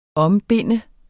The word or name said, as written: ombinde